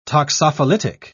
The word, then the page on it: toxophilitic: British English